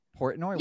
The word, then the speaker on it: Portnoy: Portnoy? – Yeah.